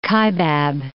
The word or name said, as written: Kaibab Plateau